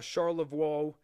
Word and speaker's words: Charlevoix: And that is spelled C-H-A-R-L-E-V-O-I-X. Does that sound like French or Italian to you all? Well, the answer is easy, French. Charlevoix, depending on how you best pronounce it.